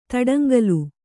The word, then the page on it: ತಡಂಗಾಲು: taḍaŋgālu